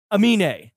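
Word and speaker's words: Aminé: We fucked up with Amine. – Yeah. – Amine. – Amine, and were saying Amine. We put the wrong emphasis on the wrong syllable.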